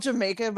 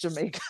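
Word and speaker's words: D'yer Mak'er: Yeah, it's called Jamaica, but if you read it, it says Dire Maker, but it's a play on the British pronunciation, Cogni pronunciation. There's a whole story behind why it's written that way, but pronounced Jamaica.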